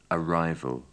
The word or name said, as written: arrival